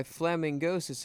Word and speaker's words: Flamingosis: that was Grooven by Fling... sorry, I mispronounced that. That was Grooven by Flamingosis and Young Bay.